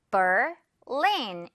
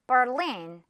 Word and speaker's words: Berlin: Berlin 이렇게 따로 생각해 주세요. Berlin. Alright. 듣고 따라해 주세요. Berlin. One more time. Berlin. 그렇죠. 이제 베를린 하지 않을 수 있겠죠, 영어로. 자, 두 번째는 스페인의 마드리드죠.